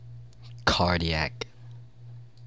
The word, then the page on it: cardiac: American English